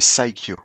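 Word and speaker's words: Psikyo: On prononce pas le P, les enfants. C'est Psycho.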